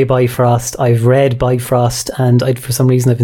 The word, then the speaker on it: Bifrost: Well, my discovery is a very simple one, really, but I discovered that the Bifrost is actually pronounced and spelled Bifrost, not Bitfrost.